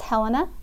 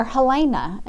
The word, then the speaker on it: Helena: it is often pronounced Helena. – Yes. – Or Helena. And so I have the long E in my name and love it. Yeah, I was happy to find out that the Helena south of Birmingham is pronounced the same way I do my name.